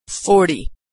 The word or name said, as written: 40